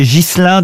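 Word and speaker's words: Ghiselin: Ogier Gilin de Busbeck, pardon, j'ai écorché son nom. – Il me semblait bien aussi. – Ça, c'est chou. Ça, oui. Est-ce que vous pouvez appeler Busbeck? – Busbeck. Ogier Gislin de Busbeck. – Ah, Gislin.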